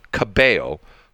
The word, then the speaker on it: Cabello: Two L's are always pronounced as Y. So Camila Cabello. So one L is Camila, and Cabello is Y.